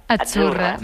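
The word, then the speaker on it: Azzurra: Achurra. Mira, ¿ves? – Achurra. – Es que... – sí, como porque pizza. La Z no es la Z como decimos en catalán tan sonora, no. Es Achurra.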